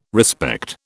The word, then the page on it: respect: British English